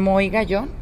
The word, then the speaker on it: Moy Gayón: Y estoy pues realmente muy agradecida porque nos dieron espacio para esta entrevista con Moy Gallón. Bien pronunciado, bien, pronunciado, muchísimas gracias.